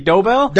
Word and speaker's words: Dobell: Yeah, it's actually Dobell.